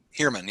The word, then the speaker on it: Heermann: Heermann, yep.